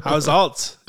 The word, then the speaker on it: Haushalt: It's run with me, H-A-U-S-H-A-L-T. Assault. – How's alt?